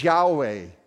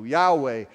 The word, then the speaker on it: Yahweh: And so YHVH is Yahweh. The V is like a W, Yahweh. And we've added the vowels from Adonai to this tetragrammaton and put it together for Yahweh.